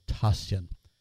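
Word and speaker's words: Tasjan: I'm not certain whether one pronounces the J as a vowel or a consonant, but it's T-A-S-J-A-N. I believe it's probably pronounced Aaron Lee Tashian.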